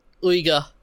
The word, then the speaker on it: Uyghur: Sorry, I'm always getting this pronunciation wrong. The Uyghur? – The Uyghur. – That's it.